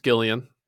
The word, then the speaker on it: Gillian: We did not have Gillian Jacobs, and it is pronounced Gillian. It looks like it's spelled Jillian, but it's pronounced Gillian.